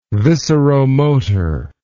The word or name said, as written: visceromotor